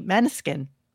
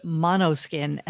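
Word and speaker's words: Måneskin: So then he starts again talking about his favorite male group, which is pronounced for me, Maneskin, right? – Maneskin. And but he likes the worst songs they do. – Howard calls it mono skin, and it drives me absolutely insane.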